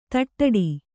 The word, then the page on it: ತಟ್ಟಡಿ: taṭṭaḍi